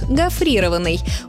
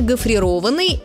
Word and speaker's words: гофрированный: При этом, скорее всего, они поставят ударение на второй слог – гофрированный. Увы, этот вариант хоть и распространенный, но неправильный. Нормативное ударение «гофрированный» – это причастие образовано от глагола «гофрированный».